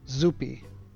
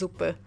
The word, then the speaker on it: zupa: I can share it, but the thing is, it's my favorite Polish word pronounced wrong. Zupi, which is not how it's pronounced. – No, it's pronounced zupa.